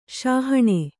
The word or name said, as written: ಸಹನಾ